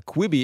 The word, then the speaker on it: Quibi: Ich muss auch nochmal sagen, wie spricht man das eigentlich nochmal? Quibi, ja, schreibt sich Q-U-I-B-I.